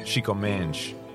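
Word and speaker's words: Chico Mendes: His face was plastered on buildings, multiple shrines, and his name still fluttered around town, affectionately pronounced Chico Menj.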